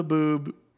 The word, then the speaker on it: haboob: And then they drive through the haboob, ruins the...